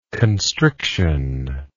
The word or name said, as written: constriction